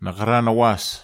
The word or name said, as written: nakharan awas